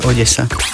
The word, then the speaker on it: Odesa: V slovenčine väčšinou D a E sa vyslovuje meko, de. A keď sa to má vysloviť tvrdo, tak je naznačená tvrdá výslovnosť. Takže ak tam nie je nič hra na tej zátvorke, znamená to, že sa vyslovuje Odesa.